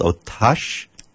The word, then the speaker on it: Otaci: But German was born in Romania in a town called Otacha. – O-T-A-C-I.